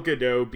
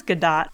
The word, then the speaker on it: Gadot: That's where, again, I think Gal Gadot being put into a position where she has to. – That's not how you pronounce her name. – Is it not? – I'm pretty sure it's Gadot.